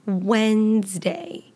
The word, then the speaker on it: Wednesday: And the way that Wednesday is spelled, it has a D in there, Wednesday. However, you're not going to hear it in the pronunciation. We never pronounce the D in Wednesday.